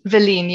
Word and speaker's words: Willene: Willeen, yes.